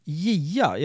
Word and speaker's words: JIA: Eh, sektion JIA, jag vet inte om jag uttalar det rätt. – Ja, men det är helt rätt och det är,